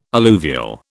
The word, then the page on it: alluvial: British English